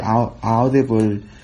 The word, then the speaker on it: Audible: Se escribe A-U-D-I-B-L-E, Audible.